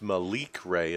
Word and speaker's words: Malik Ray: It's Malik Ray, is that right? – Yeah, that's what it is.